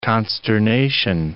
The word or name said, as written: consternation